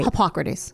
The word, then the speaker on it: Hippocrates: Hippocrates.